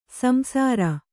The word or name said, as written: ಸಂಸಾರಿ